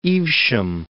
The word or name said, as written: Evesham